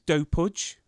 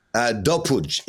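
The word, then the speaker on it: Dopud: It's pronounced Serbian, so it's Dopuj. – Dopuj is the right way.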